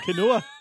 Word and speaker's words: quinoa: Oh, is it quinoa? – Yeah. – Quinoa.